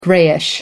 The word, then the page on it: grayish: American English